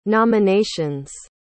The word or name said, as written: nominations